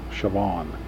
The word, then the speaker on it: Chauvin: Yeah, I think that's what Bryn Campbell called him, Chauvin. That's the way he pronounced it, the country flair.